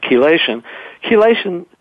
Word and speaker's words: chelation: But the high tech therapies I'm talking about, like chelation is spelled C-H-E, like chemistry. It's a hard C-H, like chemistry.